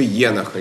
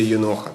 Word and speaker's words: Енох: Например, может быть, вы слышали такое название «Книга Еноха» или «Еноха», там по-разному можно поставить ударение, которая описывает откровение якобы бывшего ветхозаветному праведнику Еноху.